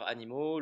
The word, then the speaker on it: Animo: Alors, c'est vrai que ça s'écrit A-N-I-M-O, mais quand on le prononce juste à l'oral, ce qui est plutôt intéressant, c'est que c'est aussi pour nous, ben voilà, ça nous ramène à, ce côté animal, mm.